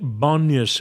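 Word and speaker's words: Banias: It is named after the Greek god of wine, Pan, but the Arabs couldn't pronounce the P, so they called it Banyas instead of Panyas.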